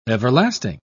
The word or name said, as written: everlasting